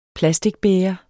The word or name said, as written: plasticbæger